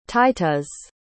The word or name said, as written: Titusz